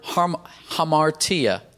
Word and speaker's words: hamartia: That word sinned in the Greek language comes from a word hamartia. Let me spell it for you. H-A-M-A-R-T-I-A. Hamartia. H-A-M-A-R-T-I-A.